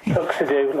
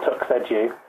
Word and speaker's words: TuxEdu: Your pronunciation there. TuxEdu, like tuxedo rather than TuxEdu.